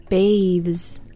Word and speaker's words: bathes: And then we have another one, baths versus bathes. – Baths. – Baths. – Bathes. – Bathes. So if you guys noticed, neither I added an extra syllable. – Right, it wasn't bathes.